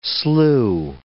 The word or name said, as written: slue